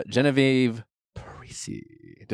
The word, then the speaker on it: Genevieve Parisi: The one, the only dear friend and friend of show, Genevieve Parisi. Did I say that right? – Yes, you did.